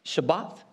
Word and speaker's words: Shabbat: Shabbat is how it's pronounced.